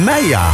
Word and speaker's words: Maya: Maya, voor de duidelijkheid. Even uitgesproken op die manier.